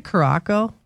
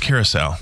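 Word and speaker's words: Curaçao: Isn't that Curacao? – No, it's Curacao.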